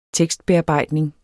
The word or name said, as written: tekstbearbejdning